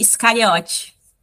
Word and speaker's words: Scariot: Como que se fala o seu sobrenome, gata? É Dani Scariotti.